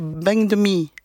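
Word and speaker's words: bánh mì: En vietnamien, le P, on ne peut pas le prononcer, donc on dit B. Donc si on prononce avec l'accent du Nord, on dirait bang de mi, bang de mi.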